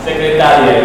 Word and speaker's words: secretaria: Si no tiene acento, ¿cómo es la pronunciación? – secretaria.